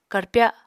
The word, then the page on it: karpya: Apatani